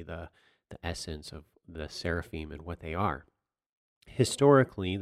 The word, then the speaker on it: seraphim: The Hebrew word seraphim is pronounced seraphim. Seraphim, seraphim. It's the exact same word.